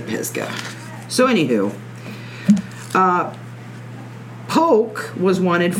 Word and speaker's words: Pisgah: I wonder if they pronounce it Pisgah or Pisa. Because up here in Ohio, they pronounce it Pisa, and it's... – No, like, it's Pisgah.